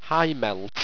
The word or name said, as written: hymelt